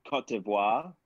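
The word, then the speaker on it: Côte d'Ivoire: And by the way, it's Cote d'Ivoire is how you pronounce that country.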